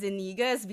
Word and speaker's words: Iniguez: I now say it as Iniguez because I don't have an enye in my Australian document. So I scrapped it.